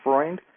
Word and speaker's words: Freund: Oh, I have great confidence in you, especially if you have a colleague who has some German background, because my surname is German. It's Freund, means friend, and, you know, Dr. Sigmund Freud, I guess, would be a good parallel to get the vowels out.